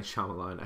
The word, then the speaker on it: Shyamalan: Night Shyamalan, I should say his name right.